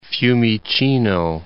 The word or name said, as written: Fiumicino